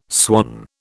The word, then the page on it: swan: British English